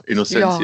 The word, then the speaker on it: Innocentia: Ja, also Innocentia Park ist, spricht man den eigentlich so aus? Was habe ich gesagt? – Ich glaube ja, Innocentia.